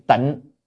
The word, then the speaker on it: tịnh: Chữ tịnh hay đọc là tinh cũng được. Trong kinh A Di Đà bản dịch ở Việt Nam thì chữ này đọc là tinh, tinh chư Bồ Tát Ma Ha Tát á. Nhưng mà trong từ điển thì không có chữ tinh, là chữ tịnh thôi.